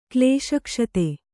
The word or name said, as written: ಕ್ಲೇಶಕ್ಷತೆ